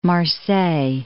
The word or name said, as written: Marseilles